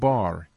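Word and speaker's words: bar (בר): Bet resh, pronounced bar, is the Hebrew word for sun.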